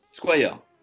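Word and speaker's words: Squier: Billy Squire. Squire, comment tu le prononces? – Squire. – Voilà, Squire, j'étais pas loin.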